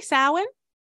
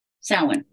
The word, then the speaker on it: Samhain: How do you say that? Samhain? – I say Samhain. – Samhain, thank you.